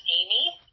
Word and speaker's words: Amy: Uh, my name is Amy, A M Y, Easterling, E A S T E R L I N G.